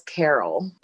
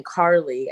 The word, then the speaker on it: Caryl: And so the spelling of my name, it's spelled like Daryl. So it's spelled C-A-R-Y-L. And so people would look at it and flip the L and the Y. And they would just start saying Carly.